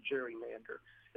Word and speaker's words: gerrymander: One of the people we quote in the book is Elbridge Gerry, who is primarily famous because he gave his name to the term gerrymander or gerrymander, as is also sometimes pronounced.